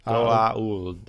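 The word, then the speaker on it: Goa'uld: Gaould. There's an apostrophe. Yeah. – Yes. Gaould.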